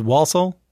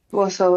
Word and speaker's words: Walsall: Yeah, move back home to is it Walsall? – Mm-hmm. You said it right. Warsaw.